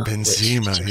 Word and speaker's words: Benzema: You know, the game was so good, I almost managed to stop being quite so irritated by the pronunciation of the English commentary of Benzema. – Benzema, yeah. That's my head in, but it's not that hard, is it? – No.